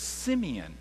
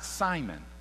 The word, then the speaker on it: Simeon: He says Simeon, not Simon. Simon is the Greek side of it. Simeon is the background kind of Aramaic side of how you would pronounce his name.